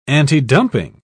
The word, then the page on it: antidumping: British English